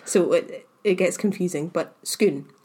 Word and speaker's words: Scone: Not Scone. – No. Skoon is spelt S-C-O-N-E. Like the baked good, but it's not pronounced that way. It's pronounced Skoon.